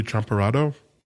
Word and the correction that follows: champurrado: Ah, el atole and the champurrado.